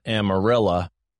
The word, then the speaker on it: Amarillo: Apparently, in this part of Texas, they pronounce the name of their town Amarilla, so it comes out Amarillo Gorillas.